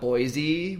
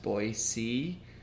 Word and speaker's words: Boise: We always call it Boise with like a Z sound, but apparently it is actually pronounced Boise.